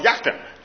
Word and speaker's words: Jachin: One was called, and I'll kind of Hebraicize it, Jachin. Okay? In English, we would say Jachin. Okay? Jachin. Well, the Hebrews would call it Jachin.